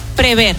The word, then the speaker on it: prever: Prever, que solemos decir prever, ¿vale? Es prever.